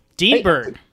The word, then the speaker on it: Dineberg: Dean Berg. – Hey, how's it going? – It's all right. I know you told me that in like a message. You're like, it's pronounced like Dean Berg.